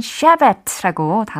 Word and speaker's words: Shabbat: 근데 여기서는 Shabbat라고 단어가 발음이 되었어요.